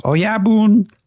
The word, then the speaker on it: Oyabun: I believe the Oyaban is referring to Shigemitsu. – Shigematsu? – Shigematsu. – Okay. – Boss. Oyaban means boss. – Perfect. – It's pronounced Oyabun. – Oyabun. That's exactly how Yukio says it.